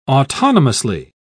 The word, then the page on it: autonomously: British English